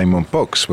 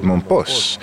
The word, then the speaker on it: Mompox: And I asked him about these two different spellings um, and also about the pronunciation because I think it's my English language roots tend to make me say Mompox when I read the, the X at the end. But then I heard him, he said Mompos.